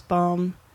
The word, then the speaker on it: Baum: No, my family's always pronounced it as Baum. Mm-hmm. Like Tannenbaum, that whole song.